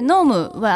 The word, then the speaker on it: gnome: うんうん、でノームは GNOME と書くんですけども、うん、まあ、 G は発音しないんですが、うん、ノームっていうね、妖精がいるらしいんですよ。